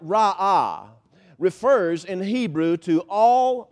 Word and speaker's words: ra'ah: The Hebrew word there is a word pronounced ra'ah. R-A apostrophe A-H is the way it's transliterated into English.